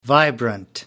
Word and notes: vibrant: American English

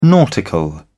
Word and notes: nautical: British English

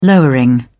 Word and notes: lowering: British English

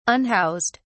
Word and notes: unhoused: British English

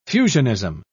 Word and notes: fusionism: British English

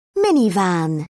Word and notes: minivan: British English

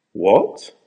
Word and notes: what?: American English

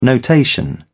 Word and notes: notation: British English

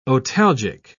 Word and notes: otalgic: British English

